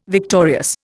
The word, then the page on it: victorious: Indian